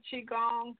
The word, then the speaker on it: Qigong: When I was first introduced to Qigong, what helped me to remember how to say it was thinking about Cheech and Chong. – I know who they are. – And that'll jog your memory as to how to pronounce it.